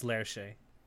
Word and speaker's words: Lerche: Lerche. Is that how you say it? – I think it's pronounced Lerche. – Lerche. Okay.